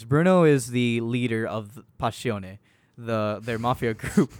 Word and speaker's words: Passione: But it's with two N's and an E at the end. So okay. – Passione. – Okay. – That's how they say it in the song.